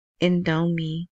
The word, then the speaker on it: Indomie: Indomie, Indomie. It's Indo, like Indonesia, and then M I E, right?